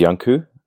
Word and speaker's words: Janku: My name is Janku. If you were an English person and you didn't know how to pronounce it, the word young and the word Coo, as in COO.